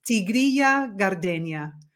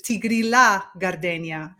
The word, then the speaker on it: Tigrilla Gardenia: And so they pronounce it the Italian way, which is Tigrilla Gardenia, not the ya.